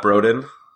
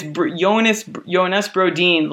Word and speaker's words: Brodin: It's not Brodin. – It's Jonas Brodin, like Sedin, I think.